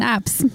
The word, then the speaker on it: apse: And I had to look up an apse. Am I pronouncing it right? APSE? – Yeah, apse. – Yeah. – Yeah. I thought I put the definition. – Not to be mistaken for an asp.